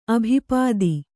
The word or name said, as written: ಅಭಿಪಾತ